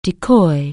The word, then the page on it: decoy: anglais